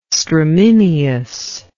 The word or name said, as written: stramineous